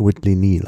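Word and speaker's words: Whitley Neill: Ich habe jetzt gerade nachrecherchiert, also es wird wirklich Whitley Neal ausgesprochen. – Okay, Whitley Neal, okay. – Ja. Das ist das Doppel-L bei Neal ist nur eine Schreibvariante, aber sonst der Vorname Neal, der ja keltischen Ursprungs ist. Und der wird Neil ausgesprochen, da gibt es gar kein Vertun.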